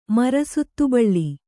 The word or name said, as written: ಮರಸುತ್ತುಬಳ್ಳಿ